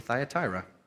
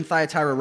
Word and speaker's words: Thyatira: There is some debate about the pronunciation. You could say it how you'd like to. I'm going to say Thyatira.